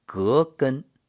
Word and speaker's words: 葛根: 葛根，正确的应该是葛根，葛第二声。